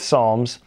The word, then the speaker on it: Psalms: So we're really wrapping up the series on Psalms where we've taken a look, we've gone through the book of Psalms, which is actually just the Book of Songs without a silent P in it in the Bible.